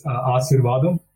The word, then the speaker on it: Asirvatham: It's Asirvadam. – Asirvadam. It's like Ashirvad.